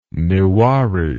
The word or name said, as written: newari